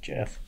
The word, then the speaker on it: Geoff: Does that look like Jeff to you? – Where at? That's pronounced Jeff.